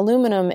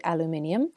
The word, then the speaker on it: aluminum: Now, aluminum is interesting, partially because Americans say aluminum and the English say aluminium, and this is not just a pronunciation change. There's actually an additional I in the English version, which explains why it's said differently.